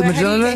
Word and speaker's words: Mjolnir: And I was thinking to myself, well, we're going to have to give him extra time because there was the what is the hammer's name? How do you say that? – Oh, how do you... Thor's hammer. – Jolnir? – It's spelled M-J-O-L-N-I-R.